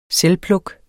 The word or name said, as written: selvpluk